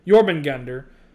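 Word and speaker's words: Jörmungandr: One of the ones he tells is that the last time in this universe that Thor fought Jormungandr, did I pronounce it right? – Mm-hmm.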